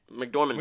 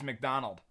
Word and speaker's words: McDormand: So it's Frances McDormand. – McDormand. – Which is great because I always, my dyslexia thought, and truly I have dyslexia, I always thought it was Frances McDonald.